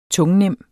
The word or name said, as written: tungnem